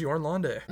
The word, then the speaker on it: Jorn Lande: And everyone gets his name wrong. – Okay. – It is Jorn Lande.